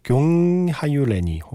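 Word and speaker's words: Gyöngyhajú lány: 욘하율레니 정도로 제가 좀 여기저기 찾아본 발음으로는 이게 제일 흡사한 것 같은데요. 왜냐하면 이게 스펠링도 어렵고요. 이게 헝가리 말이에요. 그래서 정확한 발음을 저도 찾았다고 장담할 순 없지만 제가 찾은 발음 중에서는 용하율레니 혹은 욘하율레니로 많이 읽더라고요.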